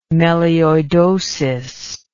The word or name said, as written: melioidosis